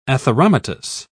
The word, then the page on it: atheromatous: British English